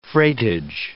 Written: freightage

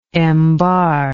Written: embar

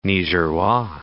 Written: Nigerois

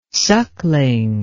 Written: suckling

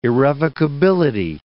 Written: irrevocability

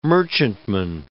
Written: merchantman

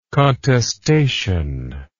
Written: contestation

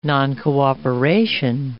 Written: noncooperation

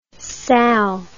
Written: salle

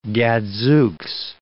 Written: gadzooks

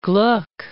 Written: Gluck

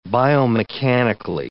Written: biomechanically

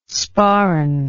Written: sporran

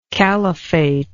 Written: califate

